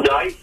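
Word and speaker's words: Z'deip: Well, my first name is Ball, but my last name is, the spelling of it is a capital Z and an apostrophe D-E-I-P. – Yeah. – And it's pronounced Dice. – Okay. – Yeah. – So if you say my name together, Ball Dyke.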